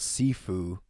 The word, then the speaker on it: Sifu: Speaking of deja vu, it rhymes with what our next game is called, and it's called Sifu.